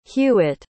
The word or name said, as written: hewett